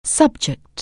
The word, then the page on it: subject: anglais